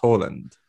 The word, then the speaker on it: Haaland: I think it's interesting that for all that Steve, who is an assiduous researcher, knows that that name is pronounced Haaland, that something deep within his black heart tells him that it's Alfinder Haaland, and that's how he wants to pronounce it.